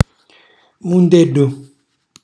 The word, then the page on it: mundellu: Calabrese